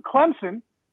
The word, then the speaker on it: Clemson: Clemson with a P. Because they say it like they have a P. It's spelled Clemson. But you have to say Clemson.